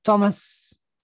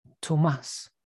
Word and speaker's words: Tomas: Thomas, remember, it's spelled T-O-M-A-S, no H, because my name comes from, well, Argentina, that's where I was born, and it's actually pronounced Thomas.